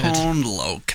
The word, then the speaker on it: Tone Loc: Tone Loc. There it is. That's how you say it. I said Tone Loc before.